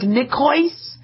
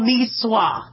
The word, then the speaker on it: Niçoise: It looks like nicoise. It's called Niçoise. I guess it's from French.